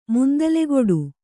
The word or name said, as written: ಮುಂದಲೆಗೊಡು